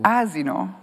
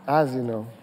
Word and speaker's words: asino: wait, wait, so how do you say donkey in Italian? – Asino. – Asino.